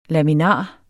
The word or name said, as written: laminar